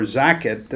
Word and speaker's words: zakat: And then the alms or zakat, did I say that right? – Mm-hmm.